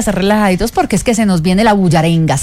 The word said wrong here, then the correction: bullaranga: Merenga, bullaranga, ¿cómo se dice? – Bullaranga. – ¿Bullaranga? – Bullaranga.